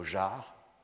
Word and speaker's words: jars: Vous avez aussi le mot jar, J-A-R-S, dans lequel le R se prononce mais pas le S.